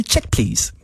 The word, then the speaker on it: CHKPLZ: Ça s'appelle Check Please. Check Please. – On est à Montréal, là, hein? Ouais. En français, s'il vous plaît. – C-H-K-P-L-Z.